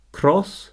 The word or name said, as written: cross